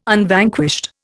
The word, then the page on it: Unvanquished: Indian